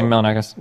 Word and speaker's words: Milonakis: I see that you debated Andy Milonakis, or how do you pronounce his name? Milonakis? I remember him back from the MTV days, long time ago. – Yeah, Andy Milonakis.